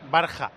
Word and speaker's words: Varga: Me dice un oyente, que debe saber del tema, que a Varga, el húngaro de la EK, le podemos llamar Barja. Porque en húngaro la G se pronuncia como si fuera una J.